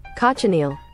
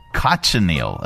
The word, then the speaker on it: cochineal: Cochineal. – Cochineal.